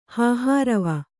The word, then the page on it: ಹಾಹಾರವ: kannada